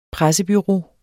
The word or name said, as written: pressebureau